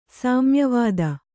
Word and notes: ಸಾಮ್ಯವಾದ: sāmya vāda

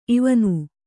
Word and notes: ಇವನ್: ivan

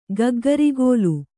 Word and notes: ಗಗ್ಗರಿಗೋಲು: Kannada

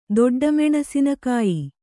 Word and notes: ದೊಡ್ಡಮೆಣಸಿನಕಾಯಿ: kannada

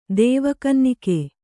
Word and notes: ದೇವಕನ್ನಿಕೆ: Kannada

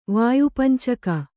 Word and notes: ವಾಯುಪಂಚಕ: Kannada

